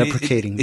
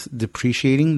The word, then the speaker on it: deprecating: But deprecating. Deprecating. No, hold on. Say it right. Because everybody says depreciating. Depreciating is like a hot devaluing.